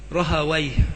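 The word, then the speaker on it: راهويه: أكثر من ينطقونها يقولون رهويه، وذكر السيوطي في التدريب أن المحدثين يكرهون مثل هذا ويقولون ويه شيطان.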